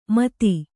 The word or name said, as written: ಮೊತ್ತು